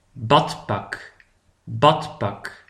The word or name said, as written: het badpak